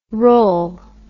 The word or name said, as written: rel